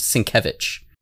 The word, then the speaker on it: Sienkiewicz: Yeah, it's spelled Sienkiewicz, but it's Polish, so it's pronounced Sienkiewicz.